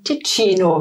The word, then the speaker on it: Ticino: Am südlichen Ende des Lago Maggiore, wo der Lago in den Ticino fließt.